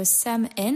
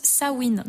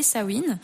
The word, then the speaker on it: Samhain: Donc, comme je disais tout à l'heure, c'est du coup une très vieille fête celtique appelée Samhain, mais en fait, tout à l'heure, enfin, apparemment, ça se prononce Samhain. Donc, tout à l'heure, je l'ai très mal dit. Donc, c'est appelé Samhain.